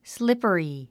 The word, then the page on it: slippery: slípəri; スリッパリィ